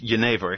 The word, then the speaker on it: genever: I know that things like Genever, I think it's called, which is the precursor of gin, comes from the Dutch. How about the chocolate? – It's pronounced Genever.